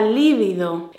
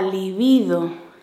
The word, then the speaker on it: libido: Pues la respuesta es que esta palabra es llana. No es la líbido, es la libido. Libido.